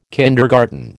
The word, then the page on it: kindergarten: American English